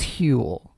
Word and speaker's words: Huel: It's not Huel. It's Huel. – How do you spell it? – H. U-E-L.